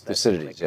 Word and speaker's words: Thucydides: The so-called Thucydides trap, if I pronounce that correctly. Yes, Thucydides, yes.